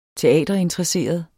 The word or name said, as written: teaterinteresseret